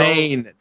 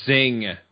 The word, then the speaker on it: Zayn: Zayn, not Zing.